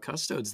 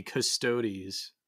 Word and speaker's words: Custodes: We even remembered to talk about Custodes this time. – Yeah, we didn't leave them out like we do on the... – Or excuse me, Custodes. Gotta get the pronunciation right for these fake words.